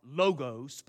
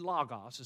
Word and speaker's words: logos: I know we like to say it in the South, logos, but it's actually logos is how you pronounce it.